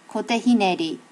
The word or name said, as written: kote hineri